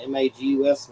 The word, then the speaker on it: Magus: How do you say that? M A G U S, Magus, Magus.